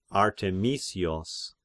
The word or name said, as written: Arthemisios